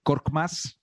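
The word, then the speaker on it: Korkmaz: Eh, suplentes notables, Shake Milton, Tyrese Maxi, ojo, Matisse Taibul, Furkan Korm, ¿cómo se pronuncia? ese propio? Korkmas, Y el gran suplente de Joel Embiid, Andre Drummond.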